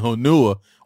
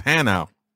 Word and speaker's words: Hanau: the Germans pronounce Hanau, and I'm saying Hanua when I thought of, you know, when they was like, it's Hanau.